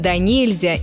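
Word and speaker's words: донельзя: Да, именно так, с ударением на второй слог – на «е». Услышать, конечно, можно и с ударением на первый слог – на «о», как в словах «доверху», «досыта». И «да нельзя» по аналогии с «нельзя». Но правильный вариант только один – «да нельзя» и никак иначе.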